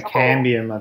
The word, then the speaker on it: Cambium: And I sit on a Brooks Cambium, I think. Is that how you pronounce it? Yeah, Cambium, I think it is, yeah.